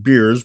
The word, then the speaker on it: Bears: We're going to meet Bruce Bears, but it's pronounced Beers, but it's spelled Bears, and it's pronounced both, depending on, well, listen tomorrow.